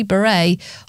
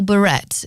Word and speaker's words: Beret: New music on the way from Kasabian on Absolute Radio very shortly, and a track from Prince, actually, Raspberry Beret, or Beret, if you fancy pronouncing all the letters.